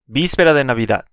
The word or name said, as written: vispera de Navidad